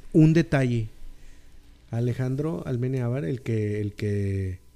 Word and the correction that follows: Amenábar: Amenábar, perdón.